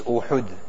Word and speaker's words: Uhud: Rasulullah sallallahu alaihi wasallam had mentioned that Uhud, generally when we speak, sometimes we err in the pronunciation according to Arabic. The correct pronunciation is Uhud.